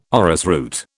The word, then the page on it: orrisroot: British English